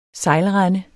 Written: sejlrende

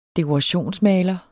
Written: dekorationsmaler

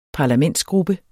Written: parlamentsgruppe

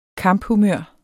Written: kamphumør

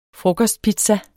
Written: frokostpizza

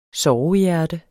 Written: sovehjerte

